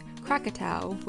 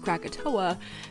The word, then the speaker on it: Krakatau: On Sunday, August 26, 1883, in what is now Indonesia, a volcano known as Krakatau, which has been somewhat westernized or mispronounced as Krakatoa, began to erupt.